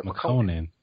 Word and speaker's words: Lil Yachty: I hope I said that right. I hate saying his name. Ugly God. – McConan. – Ugly God. Yeah, Makanin or McConan. – Yeah, McConan.